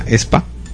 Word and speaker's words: aespa: El primero, el grupo no se llama AESPA. De hecho, se llama ESPA.